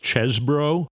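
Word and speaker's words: Chesebro: Of course, I'm talking about, I think it's actually pronounced Chesbro.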